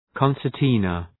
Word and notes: concertina: {,kɒnsər’tınə}

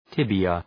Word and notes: tibia: ‘tıbıə

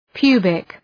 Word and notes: pubic: {'pju:bık}

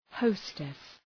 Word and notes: hostess: ‘həʋstıs

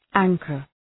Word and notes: anchor: ‘æŋkər

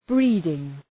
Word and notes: breeding: ‘bri:dıŋ

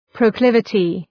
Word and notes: proclivity: prəʋ'klıvətı

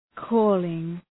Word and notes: calling: ‘kɔ:lıŋ

